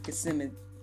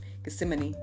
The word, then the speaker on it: Gethsemane: One of them is the prayer in Gethsemane. I knew I was going to say it wrong. Gethsemane.